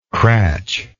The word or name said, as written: cratch